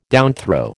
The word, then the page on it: downthrow: American English